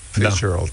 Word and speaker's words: Fitzgerald: Ți-a plăcut cum am pronunțat Fitzgerald? Fitzgerald. Da. Schmeck. – Ai pus accentul bine.